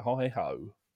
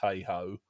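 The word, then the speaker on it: heigh-ho: But I've always wondered whether the lyricist was bothered by the fact that the singers are pronouncing it hi-ho. Whether he meant it to be pronounced hey-ho when he wrote it.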